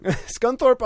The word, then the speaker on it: Scunthorpe: The Scunthorpe problem. Scunthorpe is a town in England. – Oh, it's near problem. – Yes. – It's Skunkford on problem. – Scunthorpe on problem. But when you spell Scunthorpe, S-C-U-N-T-H-O-R-P-E.